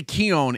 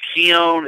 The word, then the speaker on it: Koen: And I always say Koen. Is that the correct pronunciation? – It is. It is Koen. It's spelled Koen.